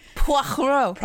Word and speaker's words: Poirot: Poirot. It's pronounced Poirot.